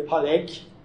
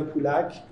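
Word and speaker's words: Pollock: پالک تو ایران معمولا میگن پولک پولک تلفظ درستی نیست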